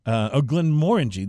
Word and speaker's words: Glenmorangie: Glenmorangie. This is what I learned last week, yes, was to say it correctly.